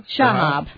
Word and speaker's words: Shahab: Well, it just says, looks Shahab? Like Shahab. – Shahab.